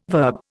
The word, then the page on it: verb: Indian